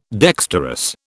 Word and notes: dexterous: British English

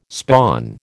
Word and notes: spawn: American English

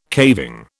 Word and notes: caving: British English

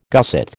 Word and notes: gusset: American English

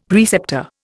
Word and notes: preceptor: Indian

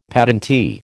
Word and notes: patentee: American English

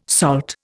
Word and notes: SALT: Indian

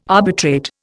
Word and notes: arbitrate: Indian

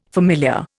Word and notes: familiar: Indian

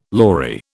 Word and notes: lory: British English

